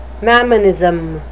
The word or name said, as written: mammonism